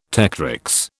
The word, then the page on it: tectrix: British English